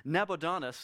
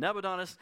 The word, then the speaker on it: Nabonidus: that the Medo-Persian army had first defeated Nabonidus, I've been trying to pronounce it, and I messed it up again. Nabonidus, about 50 miles from Babylon, just days prior to that army then going on and defeating Babylon.